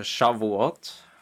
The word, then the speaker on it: Shavuot: Und zwar, wenn ich es hier richtig ablese, Shavuot, ist das richtig? – Äh, Shavuot. – Shavuot, äh, okay.